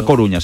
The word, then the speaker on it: A Coruña: Se calienta mucho el tema de Adama Traoré por el Deportivo de La Coruña. Este fichaje puede despertar muchas ilusiones en el Deportivo de A Coruña. – Ay, ay. – No lo he dicho bien. Bien jugado. A Coruña.